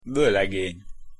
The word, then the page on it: volegeny: Hungarian